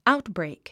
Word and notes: outbreak: áutbrèik; アウトブレイク

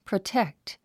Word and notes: protect: prətékt; プロテェクト

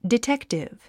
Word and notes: detective: ditéktiv; ディテクティブ

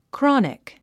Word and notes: chronic: krάnik; クロニク

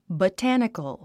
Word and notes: botanical: bətǽnikəl; バタニカル

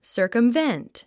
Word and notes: circumvent: sə̀ː r kəmvént; サァカムベェント

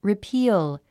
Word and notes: repeal: ripíːl; リィピーゥ

